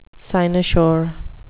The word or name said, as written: cynosure